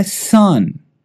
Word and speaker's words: son: Filho é son sun. É bem similar né, à pronúncia de sol, que é sun, só que com U. Né? Então com a letter U. Só que este filho aqui, a pronúncia ela é a mesma, só que é com a letra O. Então em inglês, O, letter O. E se pronuncia son.